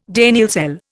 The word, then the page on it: Daniell cell: Indian